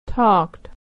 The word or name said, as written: talked